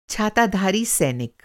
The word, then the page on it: छाताधारी सैनिक: Hindi